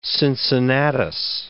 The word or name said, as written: Cincinnatus